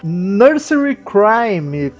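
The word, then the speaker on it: Nursery Cryme: Enfim, estamos falando do terceiro álbum de estúdio do Genesis, lançado em novembro de 71, que seria o Nursery Crime. Pronunciei correto, Daniel? – Corretíssimo.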